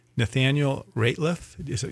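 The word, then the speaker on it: Nathaniel Rateliff: This is by Nathaniel Ratliff.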